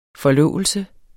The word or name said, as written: forlovelse